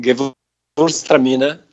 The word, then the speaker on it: Gewürztraminer: Não sei se você gosta, mas é dessa região, né? Da Alsácia também. – É, fica numa região Gewürztraminer. – Gewürztraminer? É assim que fala? – Tramina.